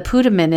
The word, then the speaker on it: putamen: Maybe the putamen is the Pluto of the body's brain. – Not Pluto men. So it's spelled P-U-T-A-M-E-N. – Uh-huh. – Putamen. – That's how we're going to say it. Putamen.